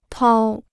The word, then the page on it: 掏: tāo